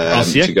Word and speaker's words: Osijek: Osijek. – Correct, Osijek, well done.